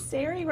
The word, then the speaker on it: Saare: Saare rhymes with fairy.